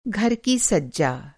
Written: सज्जा

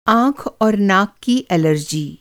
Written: एलर्जी

